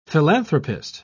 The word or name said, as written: philanthropist